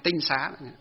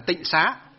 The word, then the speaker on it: tinh xá: Đó là chữ tinh xá. Sau này họ đọc là tịnh xá. Họ đọc là tịnh có nghĩa là sạch sẽ, nhưng thực tế trong nó bước đầu gọi là tinh xá.